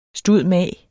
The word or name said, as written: stud.mag.